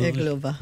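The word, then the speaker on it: Irglová: I'm glad you pronounced that. Iglova? – Iglova. – No, Iglova.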